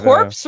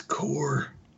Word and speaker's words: Corps: Like core of an apple? – It's pronounced core. They're corpse. Yeah. It's spelled corpse. Pronounced core. – C-O-R-P-S.